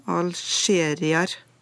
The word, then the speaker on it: algerier: Nå sa jeg det med G, da, men eh, vanlig uttale er som kjent med skjelyd. Så det blir da algerier, eller algerier, og algerisk.